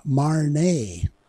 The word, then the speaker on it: Marna: I quoted Lady Marna in the past, and last week she told me she pronounced her name Marnay.